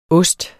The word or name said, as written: oste